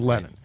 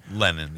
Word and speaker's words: Lenin: Plus, as I said at the top, Lenin, or see, now I'm saying it wrong. Lenin or Lenin. We're just going to go with Lenin. – Lenin, yeah.